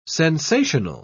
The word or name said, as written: sensational